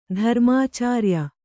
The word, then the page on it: ಧರ್ಮಾಚಾರ್ಯ: Kannada